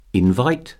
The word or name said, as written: invite